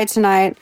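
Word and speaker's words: TNGHT: Easy, this is Buggin' by Tonight.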